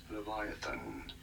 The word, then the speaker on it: Leviathan: Leviathan.